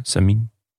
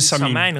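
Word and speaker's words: Samyn: Nou, dan is het op zijn Frans uitgesproken, Samin. – Ja, het is een Franse koers, dus Frans zeggen het Samijn, lijkt me.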